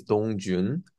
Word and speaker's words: Dongjoon: My name is Dong Jun.